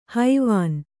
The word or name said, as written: ಹವಣ